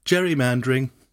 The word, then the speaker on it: gerrymandering: It was such an odd shape, people said it looked like a salamander, and the process became known as gerrymandering, or today, gerrymandering. – Well. – Because we forgot how to say his name.